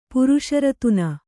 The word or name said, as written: ಪುರುಷರತ್ನ